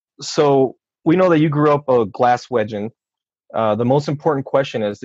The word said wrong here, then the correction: Glaswegian: Glaswegian. There we go. That's the pronunciation of that.